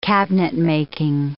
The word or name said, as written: cabinetmaking